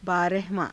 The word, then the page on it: baarahavaa~: Hindi